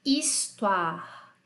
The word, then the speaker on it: histoire: O H em francês não fala, tá? Ele não é que nem em inglês, que é have, to have. Não, em francês ele é mudo total. Ele não tem. Ele só tem o som da vogal mesmo que o acompanha, tá bom? Vamos lá. Histoire.